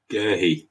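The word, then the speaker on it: Guéhi: I think he's going to pin himself on, how do you pronounce his name? Gerhi, is it? – Gerhi.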